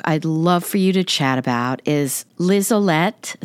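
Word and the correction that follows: Liselotte: Is that how you pronounce her name? – Oh, Lizolotta.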